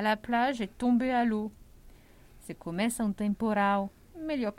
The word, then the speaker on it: eau: Água em francês é o. O. Se escreve E, A, U. Mas se fala o. A água, l'eau.